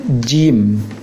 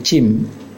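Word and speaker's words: Jim (جيم): Huruf yang berikutnya huruf jim. Pengucapannya tekankan tengah lisan ke langit-langit. Jim. Jangan sampai terucap seperti cim. Kalau bisa dikiaskan ke dalam bahasa Indonesia, ya, dengan mengucapkan J, itu jim.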